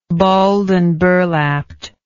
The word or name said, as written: balled and burlapped